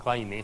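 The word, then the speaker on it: Kleene: Ja tämmöinen kaveri kuin Steven Kleine, tämä Kleine lausutaan Kleine käsittääkseni,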